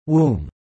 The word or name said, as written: womb